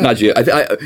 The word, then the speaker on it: Nagios: How do you pronounce that? – Nagios.